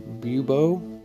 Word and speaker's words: bubo: While for supporters of the bubonic plague, it's a red inflammation around a black bubo. I think I pronounced that right. B-U-B-O-E? Bubo? – Yeah, that's how I'd say it.